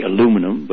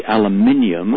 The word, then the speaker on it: aluminium: I think you say aluminum, but it should be aluminium into the brain.